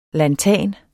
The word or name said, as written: lanthan